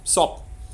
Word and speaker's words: sop: Okay, so soup in Indonesian is sop, which is just S-O-P, which I think is wonderful. You just take the U out and it becomes sop. Sop. – And sop.